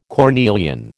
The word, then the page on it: cornelian: American English